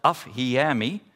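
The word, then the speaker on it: afhiemi (ἀφίημι): Het woord dat eigenlijk verreweg het vaakst gebruikt wordt, is het woord dat heet, spreek je uit als afhiemi.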